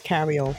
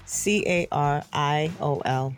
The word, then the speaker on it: Cariol: I believe the name, the young lady's name's Collier, Carolita. Carolita. – Her name is Cariol Horne. Cariol, spell it. – C A R I O L Horne. Cariol Horne.